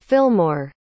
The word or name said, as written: filmore